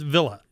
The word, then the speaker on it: Vila: Is, am I saying it right? Or uh, Villa? Close Villa? enough. It's Villa.